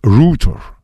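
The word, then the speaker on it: Reuters: Информационное агентство Рейтерс. Нет, дети. Когда написано бар Харрис. Это не означает Баргарис, это Баргари. То же самое агентство Рейтер. А произносится это Рутер.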